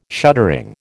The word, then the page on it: shuttering: American English